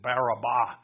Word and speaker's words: Barabbas: So let's open up first with our kind of our main idea, not kind of, it is, which is the Barabbas syndrome. Actually, in the original language, it's the third vowel that has the accent on it, so it is Barabbas.